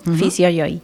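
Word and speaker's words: Fisio Johi: Pero se escribe con J-O-H-I. Fisio Yogi.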